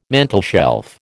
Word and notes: mantelshelf: American English

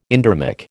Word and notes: endermic: American English

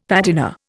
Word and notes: patina: Indian

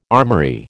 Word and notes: armoury: American English

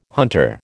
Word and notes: hunter: American English

